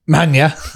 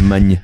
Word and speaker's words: Olivier Magne: I think it was after the Japan game where Olivier Magne, is that how you say his name? – Magne. – Yeah, Magne.